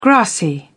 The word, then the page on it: grassy: British English